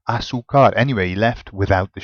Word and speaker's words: azúcar: Because he was putting the emphasis in the completely wrong place. How do you actually pronounce sugar? – Azúcar. – Can you hear where the emphasis is? – Azúcar.